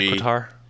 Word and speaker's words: Qatar: Qatar. How do you pronounce it? Qatar. Yeah. Qatar. – It's like guitar. – Welcome back, Qatar.